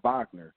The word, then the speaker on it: Wagner: It's really weird because his last name is spelled Wagner with a W, but it's pronounced with a V, Wagner, which is a little weird.